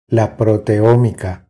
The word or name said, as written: La proteomica